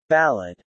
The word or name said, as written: ballad